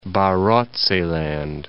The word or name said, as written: Barotseland